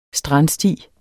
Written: strandsti

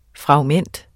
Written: FRAGMENTET

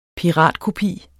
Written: piratkopi

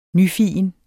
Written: nyfigen

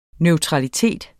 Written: neutralitet

